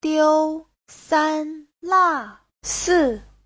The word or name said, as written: diu1san1la4si4